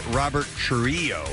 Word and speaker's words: Trujillo: Which I think the actual pronunciation of his name is Robert Trujillo. – Really? – Because it's spelled T-R-U-J-I-L-L-O, and the double L in Spanish is kind of a Y.